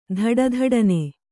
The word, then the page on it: ದುಡುದುಡನೆ: duḍduḍane